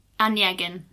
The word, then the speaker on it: Onegin: Um, basically, let me just clarify how to pronounce his name once mm-hmm. more. Yevgeny Onegin. – Onegin. Mm-hmm. Okay, Onegin.